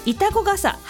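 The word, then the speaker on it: 潮来笠: まさとさんからのリクエスト、これも難読、潮が来る傘と書いてイタコ傘、橋幸男です。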